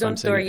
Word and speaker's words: Zumthor: It's Zumthor. You got it just right. Yeah, it's Zumthor.